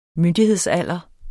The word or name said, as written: myndighedsalder